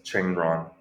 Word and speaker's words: Cheng Ruan: Yes, Cheng Ruan.